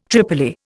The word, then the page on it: tripoli: Indian